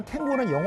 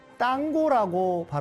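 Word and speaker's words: tango: 참고로 말씀을 드리면 탱고는 영어식 발음이고요. 스페인어로는 땅고라고 발음합니다.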